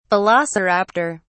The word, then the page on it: velociraptor: British English